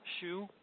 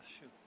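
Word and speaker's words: Xu: How do you pronounce that last name? – Shu, just like you wear Shu, you know? – X-U, yep. – Shu.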